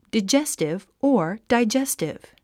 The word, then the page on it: digestive: daidʒéstiv; ダイジェスティブ